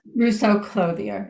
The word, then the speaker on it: Rousseau Clothier: I'm sorry, Ruth, we say your name. – Rousseau Clothier. It's Ruth.